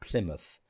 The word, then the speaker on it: Plymouth: and it sounds like mouth but pronounced Plymouth, in the UK on a zero emissions yacht.